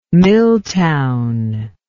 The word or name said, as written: miltown